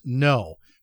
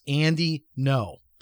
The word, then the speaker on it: Ngo: And the last name you'll see is spelled N-G-O, but it's pronounced No. So it's pronounced Andy No.